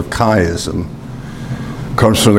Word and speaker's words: chiasm: It's transliterated CH, but it has a hard pronunciation to it, a k sound. Christ, and that's why it's called a chiasm.